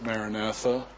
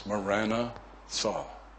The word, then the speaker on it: Maranatha: Now I know we pronounce it Maranatha, but in Aramaic, it is 2 words, Maranatha.